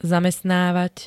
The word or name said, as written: zamestnávať